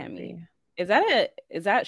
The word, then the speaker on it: Chemie: Chemie.